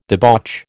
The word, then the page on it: debauch: American English